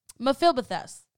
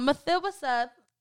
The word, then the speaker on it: Mephibosheth: David said, Mephibosheth, I think that's how you say it. Um, Mephibosheth said, Yes, sir, it is me.